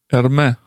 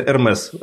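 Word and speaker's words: Hermès: Эрме. – Нет, не Эрме. Вот, извините, не Эрме. Потому что Эрмес. И такие, как есть еще... Ну, потому что так фамилия произносится. Я тоже думал, что Эрме... Нет, Эрмес.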